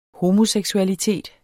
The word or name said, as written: homoseksualitet